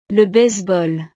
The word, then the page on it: le baseball: frances